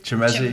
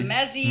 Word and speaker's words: Chimezie: How do you say the first name? – Chimezie, I think. Chimezie. – Chimezie. – Matu. – Chimezie.